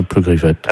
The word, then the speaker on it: Pleugriffette: Comme ça se prononce, hein, Pleugriffette.